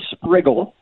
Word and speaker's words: Sprigle: His name is Ray Sprigle, Sprigle. Most people haven't heard of him, especially today. It rhymes with wiggle.